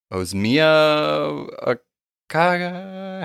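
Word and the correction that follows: Ozioma Akagha: How do you say it? – Looks like Ozioma Akaga.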